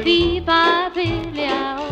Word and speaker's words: Fee Fi Fiddly I O: I think I said that right. – Fee Fi Fiddly I O. – Thank you. Uh, you say it much better.